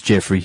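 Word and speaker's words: Geoffrey: But no, I'm not going to pronounce his name like that. Even though you can read his name like that, that's not Hinton's name like that. His name is Jeffrey Hinton.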